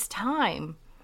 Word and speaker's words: thyme: I just want to talk about an herb that I'm growing and I really love, especially for cooking, and it is thyme. The funny thing is, it's spelled T-H-Y-M-E, if everyone's not aware of that.